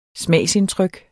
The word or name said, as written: smagsindtryk